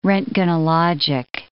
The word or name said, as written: roentgenologic